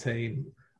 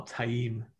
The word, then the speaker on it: Taim: There's Mazrim Tame or Taim as uh, is supposed to be pronounced and uh, And that's very unusual.